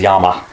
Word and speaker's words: Llama: And as she will remind you that the proper Spanish pronunciation is Yama. – That's what I was just thinking. – Yeah. – So it really is Yamas and Niyamas. – But we spell it LL.